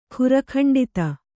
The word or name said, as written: ಖುರಖಂಡಿತ